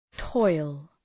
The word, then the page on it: toil: tɔıl